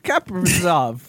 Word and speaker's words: Kaprizov: Do you think Kaprizov deserved the Rookie of the Year? – I knew it. – Could you repeat that again? – Yeah, ooh. Yeah. The Calder Trophy winner, Kaprizov.